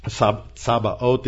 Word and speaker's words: Sabaoth: But Sabaoth, notice it starts with an S, not an SH. And it is the word that means armies or host. That OTH at the end is a Hebrew plural. And so Sabaoth,